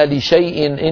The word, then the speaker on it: لشيء: ولا تقولن لشيء إني فاعل ذلك غدا في سورة الكهف، لشيء مكتوبة لام شين ألف ياء فيها ألف زائدة مكتوبة ولا ينطقها أحد أبدا،